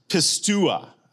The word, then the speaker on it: pisteuo: It's pistua, okay, in there. Now, you're looking at me going, yeah, I know. I don't know if it's right or not. But let me spell it for you. P-I-S-T-E-U-O.